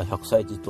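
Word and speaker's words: 百済寺: お散歩さん、滋賀には百歳寺と書いて、あ、百歳寺と読むお寺があるよ。あ、くだらじゃなくて。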